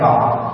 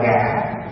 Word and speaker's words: ngã: Cũng giống như trong người Hoa viết là biết ngã, nhưng mà nói chuyện hàng ngày là nó ngọ, nỉ với ngọ đó. Nhưng mà khi viết ra chữ thì biết ngã, cho nên trong văn chương chúng ta phải đọc là ngã.